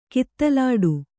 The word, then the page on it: ಕಿತ್ತಲಾಟ: kittalāṭa